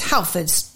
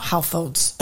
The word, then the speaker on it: Halfords: And the pronunciation is Halfords, not Halfords.